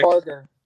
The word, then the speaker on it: Umbarger: Umbarger.